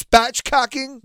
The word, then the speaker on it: spatchcocking: But I believe it's spatchcocking. – That's correct.